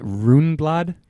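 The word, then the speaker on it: Rundblad: Is it Runblad? – It is. – Rune? – Yeah, I learned that the other day. It's a proper pronunciation.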